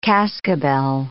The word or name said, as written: cascabel